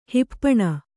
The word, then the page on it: ಹೆಪ್ಪುಣ್ಣು: hepfpuṇnu